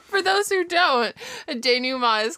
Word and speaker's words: denouement: The English word is pronounced like the French denouement. The last syllable has a nasalized vowel.